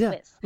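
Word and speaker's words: Whiss: Oh, Wiss. Amanda Wiss. But that rhymes with kiss. It's Wiss.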